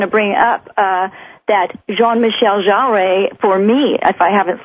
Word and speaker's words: Jarre: I think the actual pronunciation is Jean-Michel Jarre. I don't think he said Jarre. I said Jarre for years, but then I heard some French person say it, yes.